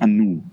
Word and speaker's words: Anu: Und zwar, du bist eine der künstlerischen Leiter von Theater Anu. Das habe ich gerade gelernt. Das, die Betonung liegt auf dem U.